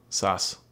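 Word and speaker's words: Saß: Katrin Saab. – No, Sass. – Is that how you pronounce that letter? – Yep, it's two S's together. It's why in the credits her name was S-A-S-S.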